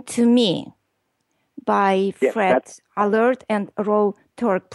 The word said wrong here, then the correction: Fred Allard: Please do a better job in pronouncing the title of the name, Ben. – Oh, Fred Allard and Rory Turk.